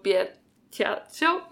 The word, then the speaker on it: Battiato: How do I say that? – Franco Battiato? – Franco Battiato, yep.